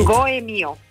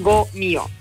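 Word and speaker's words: Gault&Millau: E Gomiu sau Goemiu? Cum... – Uh, Goemio. – Goemio, bun, deci uh, pronunțăm uh, acel... – Doar uh, ca să simplificăm, uneori pronunțăm Go-mio. Go-mio.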